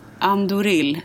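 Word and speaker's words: Ahndoril: Andoril. Andoril.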